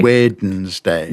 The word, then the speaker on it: Wednesday: Wednesday. – Yes. – Which is three long syllables. Wednesday is, I think, what people normally do. And they get rid of the D entirely.